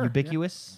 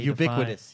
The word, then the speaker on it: ubiquitous: I don't even know how to pronounce that word. Unambiguous? – Sure. – Ubiquitous? – Yes.